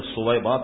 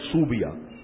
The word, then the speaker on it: صویبہ: یہ سا سے تلفظ, ہمارے یہاں یہ تلفظ بگڑ گیا. کبھی ہم نکاح پڑھاتے ہیں تو پڑھتے ہیں تو کہتے ہیں اس کو صحیح کرو, لوگوں نے صویبہ کا تلفظ کر دیا صوبیہ. صوبیہ تو کوئی نہیں, لغت میں بھی آپ دیکھیں صوبیہ کیا معنی, یہ ہے صویبہ.